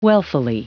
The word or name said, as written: wealthily